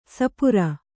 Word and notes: ಸಪೂರ: kannada

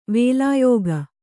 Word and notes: ವೇಲಾಯೋಗ: vēlāyōga

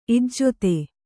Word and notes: ಇಜ್ಜತೆ: ijjate

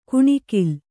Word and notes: ಕಣಗಲ: kannada

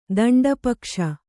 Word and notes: ದಂಡಪಕ್ಷ: daṇḍa pakṣa; kannada

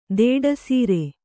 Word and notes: ದಿಟ್ಟಿಸರ: Kannada

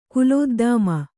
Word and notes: ಕುಲೋದ್ದಾಮ: Kannada

